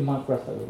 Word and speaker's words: Mark Ruffalo: so uh, Mark Ruffalo, a really famous Hollywood celebrity, tweeted yesterday. Did I say it correctly? Mark Ruffalo, right? Yeah.